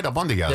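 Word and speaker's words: albóndigas: Albondigas, yeah. Right? Yeah, meatballs. – But fascinating, which I told you, that was the one thing we're going to be making tonight for dinner. And it's albondigas.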